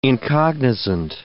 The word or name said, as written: incognizant